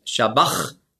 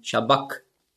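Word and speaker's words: sabachthani: Und das CH im Griechischen, das ist ja ein Buchstabe, das Chi, das hat man ausgesprochen so wie ein C hinten im Gaumen. K. Also nicht Shabach, sondern Shabak.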